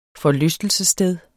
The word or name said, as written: forlystelsessted